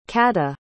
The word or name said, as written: cadha